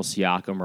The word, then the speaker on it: Siakam: Norman Powell, Marc Gasol, and Pascal Siakam are all out.